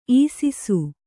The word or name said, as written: ಇಸಿಸು